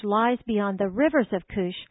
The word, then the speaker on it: Cush: It's actually pronounced Cush.